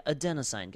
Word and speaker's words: adenosine: A chemical, did I pronounce that right? Adenosine? Yeah, adenosine, guys.